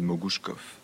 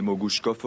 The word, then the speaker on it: Mogouchkov: Mohamed Mogouchkov aurait notamment déclaré Mon nom se prononce comme Kalachnikov.